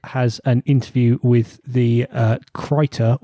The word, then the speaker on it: Krita: Krita, this is Krita. This is the... do you keep correcting me? Am I saying it wrong every time? – Krita. – Krita. I'll get there eventually.